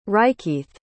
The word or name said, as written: rikeeth